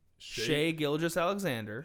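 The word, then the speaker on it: Shai Gilgeous-Alexander: but they have their franchise cornerstone in Shea Gilgeous-Alexander.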